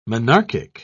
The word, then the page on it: monarchic: British English